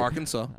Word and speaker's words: Arkansas: Arkansas.